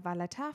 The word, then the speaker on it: Valletta: Und zwar ist die Hauptstadt mittlerweile Maleta, äh, Valletta.